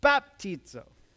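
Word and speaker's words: baptizo (βαπτίζω): It's on the first syllable. So, baptizo.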